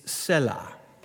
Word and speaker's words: Selah: There is a consensus out there, and this is my preference. It's Selah. Selah.